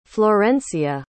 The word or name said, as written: florencia